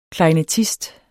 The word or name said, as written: klarinettist